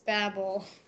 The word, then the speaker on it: Babel: Is it Bab? Huh? Or Babel? – It's Babel. – Babel, like the Tower of Babel? At least I think that's the pronunciation.